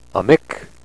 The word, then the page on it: amik: nishnabe